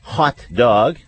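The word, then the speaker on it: Haute Dog: Today our guest is Justin Rudd, a Long Beach-based dog-minded activist and organizer who founded a few different organizations, actually, one including Hot Dog. Let me quickly point out that's spelled H-A-U-T-E.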